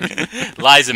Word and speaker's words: Liza: I don't know if she's exactly the draw, but it's Sally Bowles, played by Lisa. I was about to say her name again. Sorry, Liza Minnelli, mm-hmm.